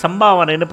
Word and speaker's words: சமபாவனை: இப்போது முந்தின ஸ்லோகத்தில் சொல்லும்போது நான் அந்த சமபாவனை வந்து சம்பாவனைன்னு ப்ரொனவுன்ஸ் பண்ணியிருப்பேன், சாரி அது வந்து சமபாவனை அப்படி தான் நம்ம எடுத்துக்கணும்.